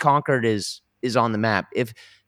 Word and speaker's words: Concord: It's spelled Concord, yeah. But it's pronounced Concord, right? – Concord.